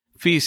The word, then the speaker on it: Fi: Fi se escribe FI como Fantástico Internacional.